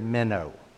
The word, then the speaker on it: meno: The Greek word here for abide or to remain is the word meno, all right? It's pronounced just like the little fish that swims that we use as bait, but it's M-E-N-O is the way it's actually spelled, meno.